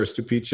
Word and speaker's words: Stupice: so stupice. – Yeah, it's it's pronounced Stupice or Stupice.